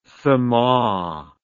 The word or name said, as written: sama